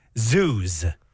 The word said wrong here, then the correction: zhuzh: Z-H-U-Z-H. Zoos. – Oh, goodness. – You'll zoos up something. What does that mean? – Oh, you're mispronouncing it. Judge. – Is it judge? – Yeah, you judge it up. Yeah, you fancy something up if you're... – Judge? – Yeah, judge. Okay.